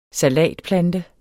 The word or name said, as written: salatplante